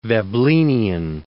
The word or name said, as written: Veblenian